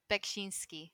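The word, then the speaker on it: Beksiński: I think you said, I'm going to butcher this name, Bekshishi? – Bekshinsky. – Bekshinsky.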